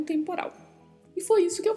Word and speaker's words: temporal: Temporal. Mais uma particularidade aí que é a pronúncia do L, né? Não podemos esquecer, com a ponta da língua tocando a parte superior da boca.